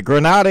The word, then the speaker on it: Granada: The origin of the name Grenada comes from the Spanish city of Grenada, or Granada. Granada is the Spanish pronunciation, and that means pomegranate.